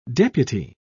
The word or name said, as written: deputy